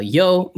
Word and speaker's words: Yeoh: It is the new Michelle Yeoh movie.